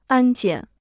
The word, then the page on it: 安检: ān jiǎn